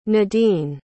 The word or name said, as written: nadeen